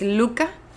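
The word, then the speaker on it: Lykke: Sí, aquí se llama The Little Book of Luca. Se escribe Lick. Digo, está raro. Y tuve que meterme un videito de YouTube ahí para escuchar cómo lo pronunciaba. Y es Luca.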